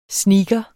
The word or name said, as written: sneaker